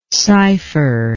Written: cypher

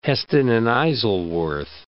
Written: Heston and Isleworth

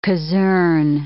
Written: casern

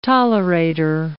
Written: tolerator

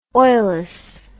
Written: oilless

